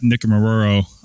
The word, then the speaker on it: Nikumaroro: Nikumaroro Island.